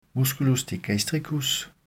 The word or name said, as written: m. digastricus